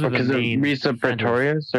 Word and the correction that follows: Resia Pretorius: Yeah, uh, like Resia Pretorius and um, the people in uh, Berlin who are doing.